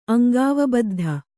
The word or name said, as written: ಅಂಗಾವಬದ್ಧ